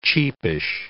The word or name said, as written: cheapish